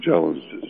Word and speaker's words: Morgellons: Yes, Morgellons disease.